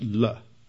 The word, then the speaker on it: la: In the Hebrew, the word David is prefixed by a preposition, the letter lamed in Hebrew, which is pronounced just la. La.